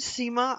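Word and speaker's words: Sima: My name is Seema Albuye.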